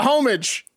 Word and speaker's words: homage: It's a homage. – Did I say homage or homage? – I think you said homage. – Homage. – Yeah. – It's Amish. – It's a silent H, I believe.